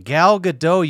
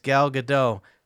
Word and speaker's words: Gadot: And I was like, no, it's Gal Gadot. And she was like, no, I'm fucking civilized. I'm American. And I was like, no, it's not Gal Gadot, you dumb motherfucker. No one pronounces a T except for America.